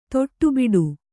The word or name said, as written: ದಡಬಡ